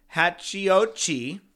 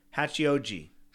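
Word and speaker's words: Hachiōji: Hachio with a long O, J-I. Hachioji. – Hachioji. – That's what I just said. – Hachioji.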